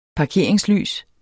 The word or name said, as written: parkeringslys